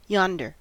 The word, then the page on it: yonder: American English